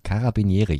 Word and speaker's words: Carabinieri: Carabinieri.